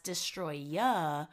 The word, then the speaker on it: Destoroyah: Because in the movie, it's subtitled as Destroyer, and they just pronounce it in Japanese as Destoroyah.